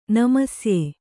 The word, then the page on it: ನಮಸ್ಯೆ: namasye